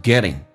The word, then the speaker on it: getting: Alright, 我们来看一下第一个 getting，在美式发音当中发成 getting，两个元音中间所加的 T 要变成 D 这个发音，而且把后鼻音在口语化当中经常发成前鼻音，叫 getting getting，所以你会发现这个说起来更加的轻松，甚至有的老外刷成 getting getting or again getting started。